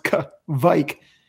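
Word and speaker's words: Kveik: but in terms of Kvike, it's K-V-I-E-K, and it's pronounced Kvike.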